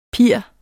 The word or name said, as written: pier